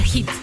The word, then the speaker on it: Heat: El mensajero que está en la aplicación de Cadena Hit, desde cualquiera de las plataformas de descarga, le encuentran como Cadena Hit. Hit se escribe H-E-A-T.